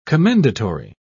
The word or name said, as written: commendatory